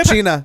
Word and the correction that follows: Coachella: These damn kids are Coachella.